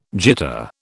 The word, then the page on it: jitter: British English